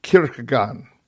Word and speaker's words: Kierkegaard: And one of the major people there that influenced Protestant Christian mysticism was a Danish theologian that we've covered a couple times, Søren Ave Kierkegaard. That's how you pronounce Kierkegaard's name. Kierkegaard, I believe.